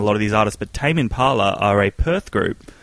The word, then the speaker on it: Tame Impala: The Crystal Method, Moby, Tame Impala, is that how you say it? – Tame Impala? – Yeah.